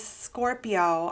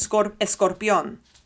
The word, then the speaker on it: Escorpión: My name is Miss Scorpio. I'll say, my name is Miss Escorpión. Mm-hmm. Right? Like, I'll say my last name the way it's supposed to be pronounced.